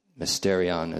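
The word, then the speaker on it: mysterion (μυστήριον): And mysterion is the long E also.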